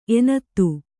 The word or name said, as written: ಎನತ್ತು